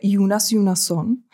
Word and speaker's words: Jonas Jonasson: Jonas Jonasson se vyslovuje Jonas Jonasson.